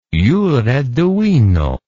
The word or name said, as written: jure divino